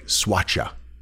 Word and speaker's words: Soacha: It's spelled S-O-A-C-H-A, Soacha. Soacha.